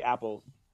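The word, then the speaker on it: Appel: Epic Appeal is the film's director, or excuse me, Eric Appeal. I can read, swear to God. – I think it's Eric Apple. – Is it pronounced Apple? Yeah. Okay. – I think it's Apple. – Okay, it's spelled Appeal.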